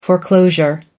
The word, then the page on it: foreclosure: American English